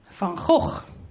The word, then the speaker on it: Van Gogh: How would you pronounce Van Gogh? Van Gogh. That is fantastic.